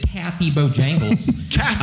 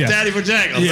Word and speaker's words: Tatty Bojangles: It's not Taffy. – It's Tatty. – Taffy. – Bojangles. – Tatty. Oh, am I saying it right? It's Taffy. – Yeah. Yeah. You know, tit for tat.